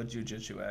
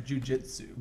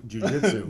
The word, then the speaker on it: jujitsu: I like that I'm sitting here with you and we're having this conversation all as a result of a jujitsu accident. – Yep. – And I said that wrong. Jujitsu. – Jujitsu.